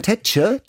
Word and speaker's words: Tetje: Also du würdest sagen Tetje? Tetje. Tetje, ja. also mit langem E, Tetje.